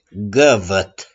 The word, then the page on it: GĂVĂT: s.n.